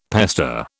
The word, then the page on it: pester: British English